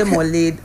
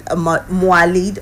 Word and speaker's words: Mawlid: You can say Mawlid, Mawlid or Malud.